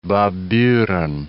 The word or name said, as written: Baburen